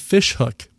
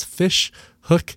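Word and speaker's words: Ghoti Hook: This first song is by a group called Fishhook, but if you're spelling it F-I-S-H or P-S, I'm sorry, P-H-I-S-H, you'd be wrong. It's actually spelled G-H-O-T-I, hook. The G-H is spelled like the f sound in enough, the O is spelled like the I sound in women, and the T-I is spelled like the sh sound in nation. So it's Fishhook, spelled G-H-O-T-Y.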